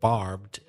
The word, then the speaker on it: barbed: As I mentioned before, we heard the story, the word barbed, be careful about not pronouncing the E.